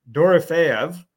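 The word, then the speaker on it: Dorofeyev: And I paired him up with Dorofeev, I believe I said that right. – Yep, you did.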